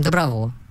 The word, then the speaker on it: доброго: Ну, ударение же логичное, когда говорят добровольцы. Поэтому всего вам доброго.